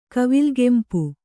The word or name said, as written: ಕವಿಲ್ಗೆಂಪು